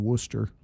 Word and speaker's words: Worcester: And that would have been probably somewhere around Worcester.